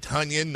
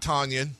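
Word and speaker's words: Tonyan: And he and Aaron Jones and Devontae Adams and Robert Tunyon, not Tanyan, rhymes with Funyon.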